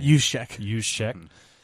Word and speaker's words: Juszczyk: Yeah, I mean, Kyle Juszczyk. I can never say his name. – Juszczyk.